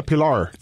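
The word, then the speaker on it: Pillar: Claro, con dos L's. – Pilar. – Sí. No, en inglés se pronuncia Pilar.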